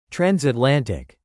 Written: transatlantic

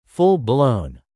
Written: full-blown

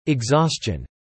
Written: exhaustion

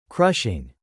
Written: crushing

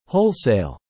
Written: wholesale

